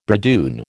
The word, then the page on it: bridoon: American English